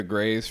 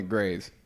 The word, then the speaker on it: Graze: Grays. The reason that I'm dumb is because Grays is spelled G R A Z E.